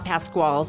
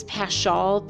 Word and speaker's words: Paschal: So this year it is the Paschal or Paschal full moon, not Pasqual. Sorry, I said that wrong. I think it's Paschal, P-A-S-C-H-A-L full moon.